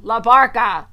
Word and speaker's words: LaBarca: LaBarca.